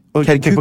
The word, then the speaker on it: Kirkuk: Og Kerkik, hvor ligger det egentlig? – Kirkuk, på Kirkuk.